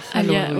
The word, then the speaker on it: halwa: tu peux parler de la halwa, je ne sais pas si je prononce bien. – C'est ça, la halwa.